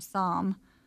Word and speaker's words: Psalm: They have a boy named Sam. Because they're super into the Bible right now. So it's pronounced okay. Psalm, like somber. Yeah. The P and the L are silent.